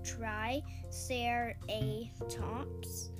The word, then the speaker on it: Triceratops: Okay, Triceratops, pronounced Tri-Ser-A-Tops. Name means three-horned face.